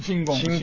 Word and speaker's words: 親近: 新言、新金って書いてね、親近、親近いって書いて、新言って読むんですね。